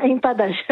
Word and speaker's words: empadas: Et puis alors, on va terminer avec les empadas, c'est comme ça qu'on prononce? – Ah, empadas, oui.